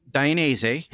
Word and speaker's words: Dainese: You've danced around that, but you work for a company, Dainese.